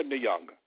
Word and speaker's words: Nyong'o: So Lupita Nyong'o.